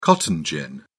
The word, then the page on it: cotton gin: British English